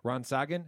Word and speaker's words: Rasengan: Rasengan.